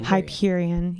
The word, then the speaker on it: Hyperion: Hyperion.